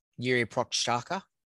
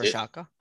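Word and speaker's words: Jiří Procházka: I don't know, have you pronounced his name before? – Jiří Prochazka. – Yeah, Prochazka. That's Prochazka. I always struggle with some of these names, but yeah, Prochazka.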